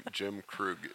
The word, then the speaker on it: Jim Krug: Yeah, it's like my full name, Jim Krug, is two syllables.